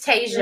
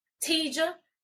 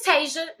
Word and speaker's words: Tasia: If you say it, you know, it's spelled, you know, people spell it T-A-S-I-A, but, you know, most of the people I'm around, they only see it spelled like at work. Somebody call me like, hey, Tija, I got your customer on the phone, blah, blah, blah. I'd be like, hey, this is Tasia. My name's Tasia.